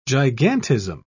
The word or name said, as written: gigantism